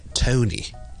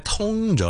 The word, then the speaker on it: Tony: Tony, and then we'll put them together and we'll get Tony. Tony. Okay? 就不是呢我们很多一般香港人会将它读成 Tony, 一通了就变成另一个音，不是通，是 toe, 加个 N 在后面就变成 tone, Tony.